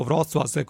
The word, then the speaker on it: Wrocław: Wrocław, così è scritto, Wrocław, così si pronuncia,